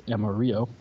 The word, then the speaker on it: amarillo: Amarillo means yellow in Spanish when you pronounce it correctly as Amarillo.